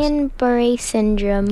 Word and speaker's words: Guillain-Barré: Give us that proper pronunciation again. You got to teach us English, guys. – Guillain-Barré syndrome. – Guillain-Barré? – Barré. Yeah. – Barré. Yeah. Okay.